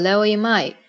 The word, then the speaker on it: Leo Imai: 最后要介绍的这位年纪最小的成员是个八零后，叫 Leo 金井，日语里面读作 Leo Imai。